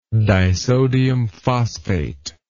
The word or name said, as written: disodium phosphate